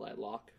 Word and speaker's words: lilac: Is it lilac? – Yeah. It's lilac, yeah. – Like? – Yeah. – Are you sure? Not lilac? – No, it's lilac. – All right.